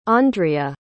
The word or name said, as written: ondrea